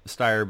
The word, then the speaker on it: Steyr: Steyr.